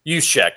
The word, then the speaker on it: Juszczyk: Jerzik what's how you pronounce his last name Juszczyk yeah You.